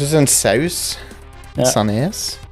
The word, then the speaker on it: Sandnes: Prøv å uttale det med trykk på siste delen av ordet. Sandnes. – Sandnes. – Sandnes? – Sandnes. Så det høres ut som en saus.